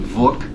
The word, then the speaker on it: Vook: We had somebody there from Vook, which rhymes with book, and is creating what they call enhanced books.